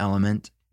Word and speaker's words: LMNT: Have you heard about Element? It's spelled L-M-N-T.